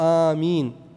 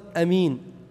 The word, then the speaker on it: آمين: طيب قال ويجهر الكل بآمين في الجهرية، الكل يعني الإمام والمأموم والمنفرد، وآمين هكذا تنطق آمين، فلا تقصر في قول أمين ولا تشدد الميم فيقال آمين، لأن أمين خلاف الخائن، اتفقنا في ياسر عبد الله.